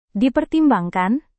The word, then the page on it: dipertimbangkan: Indonesian